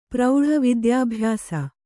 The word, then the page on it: ವಿದ್ಯಾಭ್ಯಾಸ: vidyābhyāsa; Kannada